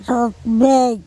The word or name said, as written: THotbEc